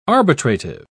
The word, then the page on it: arbitrative: British English